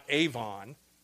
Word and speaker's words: avon: The Hebrew word for iniquities is a word that's spelled, that's transliterated as A-V-O-N, not Avon, but Avon. Okay, that's how it's pronounced in the Hebrew.